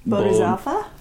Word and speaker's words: Bodhisattva: I said this wrong at the bar the other night, but it's Bodhisattva? How do you say that? You're smart. How do you say that? – I would say... – Bodhisattva? – Yeah, exactly. – Bodhisattva.